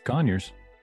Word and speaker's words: Conyers: Did I say that right? Conyers, Georgia? – Yeah. Oh, it's Conyers.